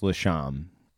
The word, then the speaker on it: Lashomb: So if you're in Ogdensburg, New York, it's Lashomb.